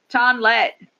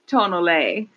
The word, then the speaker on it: tonnelle: Or when I looked up the pronunciation, all the English versions were the tonlet. But tonlet. Sounds like something someone in the West would say, the tonlet. – But the French pronunciation is like tonnelle.